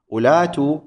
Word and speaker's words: أولات: المثال الثاني، أولات تلفظ بهمزة ثم لام ثم ألف ثم تاء، بينما تكتب بهمزة ثم واو ثم لام ثم لا ألف ثم تاء، جيد.